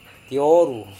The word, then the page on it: tioru: Serewen